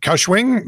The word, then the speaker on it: Kaohsiung: The man, a migrant worker from the Philippines, was quarantining in a hotel in Kaohsiung City. I don't know how to pronounce that. So that Kaohsiung. – Kaohsiung? – We'll call it Kaohsiung.